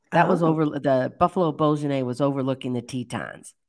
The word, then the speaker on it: bourguignon: And one time I had buffalo bourguignon.